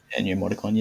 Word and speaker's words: Ennio Morricone: Ennio Morricone, yeah.